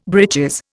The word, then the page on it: breeches: Indian